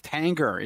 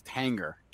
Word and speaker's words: Tanger: First, thank you to someone in the chat who told me I was pronouncing the name of Tanger Outlets wrong. I apologize. It's Tanger. It's not French. No, I always thought it was like Tanger or Tangier. It's Tanger. So, okay.